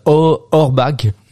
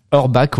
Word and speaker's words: Auerbach: Don Auerbach. Ouais, Bach justement à la fin, non Orbach, ouais.